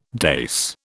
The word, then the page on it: dais: British English